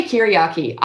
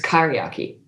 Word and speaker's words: kyriarchy: So you say curiarchy. I've only ever heard it pronounced as kyriarchy, but it doesn't really matter.